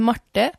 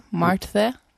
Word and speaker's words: Marte: Marte. – Marte. – But it's like Martha, but with an E. Marte.